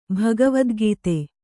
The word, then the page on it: ಭಗವದ್ಗೀತೆ: bhagavadgīte; kannada